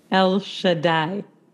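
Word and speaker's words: El Shaddai (אל שדי): El Shaddai. – El Shaddai.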